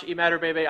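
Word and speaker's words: Imatorbhebhe: I don't know if I said that. – Josh Emmaturbebe. – I butchered that. – Yeah, it's Josh Emmaturbebe.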